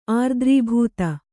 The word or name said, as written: ಆರ್ದ್ರೀಭೂತ